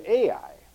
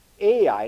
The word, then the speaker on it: Ai: having Bethel on the west and Ai. Now that's spelled H-A-I, but it's sometimes in Hebrew the H is put in, sometimes it isn't in the translations, and this Hai is the same as Ai, and that was on the east.